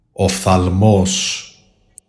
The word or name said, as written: OPTHALMOS